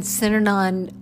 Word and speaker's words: Synanon: Can you explain to me about, and correct me if I say this wrong, Synanon, you said? – Yes, I lived in Synanon 20 years, and 6 years before that, I was a volunteer.